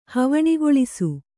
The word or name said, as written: ಹವಣಿಗೊಳಿಸು